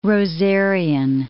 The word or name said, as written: rosarian